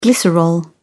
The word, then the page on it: glycerol: British English